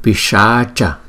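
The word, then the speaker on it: Piśāca: Pishacha Graha, chúng ta thấy chữ S có dấu dấu sắc thì chúng ta đọc là S, như là sugar, sugar, sugar là chữ đường của tiếng Anh, đó là sugar.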